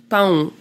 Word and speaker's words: pão: Parce que le mot pain, c'est pau, non, pão. Pão, quelque chose comme ça. Et l'autre, qui est très proche, pensez pau, et ça veut dire pénis.